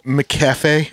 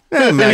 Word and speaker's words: McAfee: Yeah, she's at McCafe. – Yeah. – McAfee, he says McAfee, but that doesn't matter.